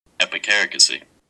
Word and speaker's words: epicaricacy: Epicaricacy.